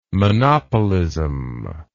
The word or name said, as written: monopolism